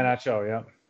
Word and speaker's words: Iheanacho: I apologize, but is it Iheanacho? – Yeah, Iheanacho.